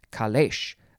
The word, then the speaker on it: caleche: It is spelled C-A-L-E-C-H-E, and there's an accent over the first E. Kalesh.